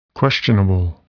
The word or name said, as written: questionable